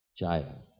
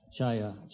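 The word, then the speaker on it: chāyā: Chaita, how did you say it? Chaya. – Chaya.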